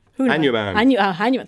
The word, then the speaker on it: Hanuman: Hanuman, thank you, John.